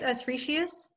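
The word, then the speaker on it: Eschrichtius: I think it's Escretius. – Escretius, yeah, that sounds right.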